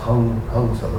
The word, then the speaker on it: Hamsa: The Hamsa, it's pronounced Hamsa. When there's a dot over the M, it's not pronounced M. You know, it's Hamsa.